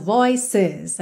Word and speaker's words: voices: É interessante reparar que esse é pronunciado, voices. A gente realmente tem duas sílabas aqui, ok?